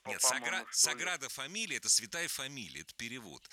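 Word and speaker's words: Sagrada Família: Саграда фамилия, вот сам собор, вот этот сейчас у меня вылетел из Нет, нет, почему вы правильно сказали, Саграда фамилия.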